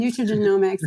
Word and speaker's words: nutrigenomics: So I'll recommend some nutrient ge- nutrient genomic testing. I'm not saying that right. – Um, nutrigenomics. Um, I am like um, I'm totally hormone space brain right now.